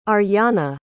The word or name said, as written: Aryana